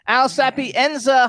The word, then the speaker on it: Al Sapienza: Now we want to welcome to the Show with Ron Russell, Al Sapienza.